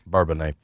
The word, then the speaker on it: Bourbonnais: Bourbonnais, fool.